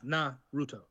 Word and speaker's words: Naruto: So yeah, you put the emphasis on the na. So it's Naruto.